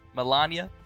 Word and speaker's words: Melania: How do you pronounce her name? Melania. – Yeah.